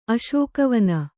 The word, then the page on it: ಅಸಗವನೆ: kannada